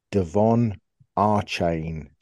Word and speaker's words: Achane: I think Joe Shad actually spoke to him, and I think his name is pronounced Devon Achane. So A-H-H-Chane. So Achane.